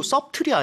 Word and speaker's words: subtle: 그리고 subtle이 아니네요, 발음이. – Yeah, that's right. The spelling is S-U-B-T-L-E. But we almost don't hear the B, so it's subtle.